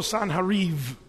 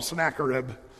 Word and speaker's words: Sennacherib: And also Sanherib? Well, we call him Sennacherib. I like to change it to the way they really pronounce it.